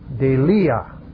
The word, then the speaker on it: deilia: Now here is the word, not for phobos, not the idea of trepidation, but rather the word, let me spell it for you, D-E-I-L-I-A. Delia.